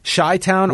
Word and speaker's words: Chi-Town: The first thing is Chi-Town pronounced Chi or She-Town? Chi-Town. Chi-Town? – Well, Chi, because it's Chicago. – She-Town. – So is it She-Town or they're saying Chi-Town. The Chi-Town Rumble.